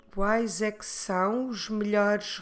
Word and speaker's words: melhores: we need to change melhor into melhores, melhores. Como se escreve melhores? M-E-L-H-O-R-E-S.